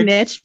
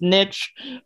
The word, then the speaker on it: niche: I like niche because it rhymes with rich. And so you can do all sorts of wordplay. – Very nice. – I love that. I love that. So it's niche from this point forward.